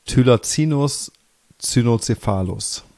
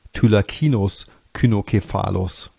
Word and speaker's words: Thylacinus cynocephalus: Oder so wie ich es damals noch gelernt habe, Thylacinus cynocephalus. Da wurde das C noch wie ein K ausgesprochen, aber ich glaube, das ist nicht unbedingt die Standardnorm.